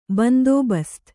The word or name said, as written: ಬಂದೋಬಸ್ತು